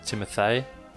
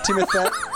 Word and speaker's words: Timothée: It's actually pronounced Timothée. – Is it? Timothée. Timothée Chalamet.